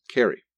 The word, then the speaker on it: Carew: Although spelled Carew, the family pronounced this name Carey.